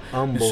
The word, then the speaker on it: Humble: Yeah, those folks in Houston don't pronounce the H, right? It's Humble.